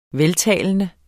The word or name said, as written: veltalende